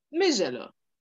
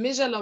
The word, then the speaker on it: Michelo: So the CH is kind of like zh. It comes off as zh most of the time. Okay. So it's spelled Michelo, but it's pronounced Mijelo. So I'm Mijelo Malambo, but my media name or my alias is Michie Zambia's sweetheart.